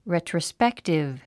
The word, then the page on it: retrospective: rètrəspéktiv; レトロスペクティブ